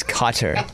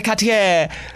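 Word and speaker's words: Kotter: Let me C-A-T-E-R. – Cater? Welcome back, Cater? – Cotter? How do you spell Carter? – No, it's Cotter. Or as spell it. – John Travolta would say, Mr. Cotter.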